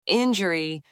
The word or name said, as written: injury